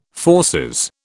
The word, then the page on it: fauces: British English